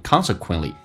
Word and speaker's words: consequently: Consequently 指的是因此结果，那么英式发音叫做 consequently, 美式发音叫 consequen, 那个发 a 这个发音 consequently, 但是共通的是 t 都不发音，当 t 跟 l 在一起 t 基本上是不发音的，就好像 exactly 你不会发现 exactly 一样的道理。这个单词 consequently, 因此 I don't put up roadblocksTo what you do.